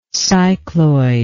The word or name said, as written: cycloidally